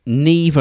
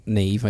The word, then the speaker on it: Niamh: You had to like because her name is not spelt Neve. It's spelt N-I-A-M-H.